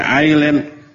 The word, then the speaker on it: island: Our dibacanya hour, ail dibacanya ada s-nya, s-nya tidak diucapkan. Island ada island harusnya, tapi diucapkannya island.